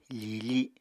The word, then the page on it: fiifi: apatani